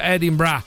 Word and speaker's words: Edinburgh: Vivo a Edimburgo, che sì. Si pronuncia Edinburgh.